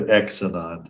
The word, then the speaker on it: Xenon: X-E-N-O-N. X-non. – X-E Xenon. Xenon. – Xenon and Xenon. – Xenon is probably pronounced as Z. It's generally pronounced as Z. – Yeah, it's usually pronounced like a Z.